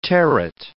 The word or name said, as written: terret